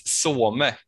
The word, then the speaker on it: Soume: Donny heter at Daniel Joanno och Christian heter, ska se om jag uttalar det rätt, att Some Christian. Rätt så Christian? – 10 poäng.